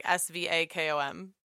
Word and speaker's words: Svakom: Okay, it's called the Svacom. I don't know how to say it. Gaga Camera Vibrator. So like Svacom, like Svakom.